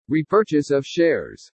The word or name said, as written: REPURCHASE OF SHARES